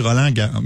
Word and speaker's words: Roland Garros: Je pense que tu prononces peut-être pas les mots. Je pense qu'on dit Roland Garros.